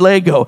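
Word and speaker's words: lego (λέγω): Say is actually the word lego. That's how you even pronounce it in Greek, is lego.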